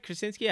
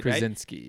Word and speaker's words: Krasinski: And then, you know, you look at John Krasinski, right? Like, yeah. – Did I say that right? Krasinski? I did. – Krasinski. – Right? Krasinski? Yeah. Yeah.